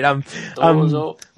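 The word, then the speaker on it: Dozo (どうぞ): I want to make sure I pronounce it right. – Dozo.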